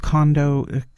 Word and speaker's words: Congo: So World Cup continues, and of course tomorrow the England will be facing the Democratic Republic of Congo. Yes, that's how it's pronounced. Not the Conga line.